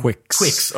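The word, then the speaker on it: Qix: Did we ever figure out how to pronounce that game? – Quix. – Okay, not like Quix. – People debate about it, but on the Japanese box art, it has the phonetics written out. So Quix.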